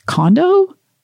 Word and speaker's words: Kondo: Is that right? Kondo? – Right. K-O-N-D-O.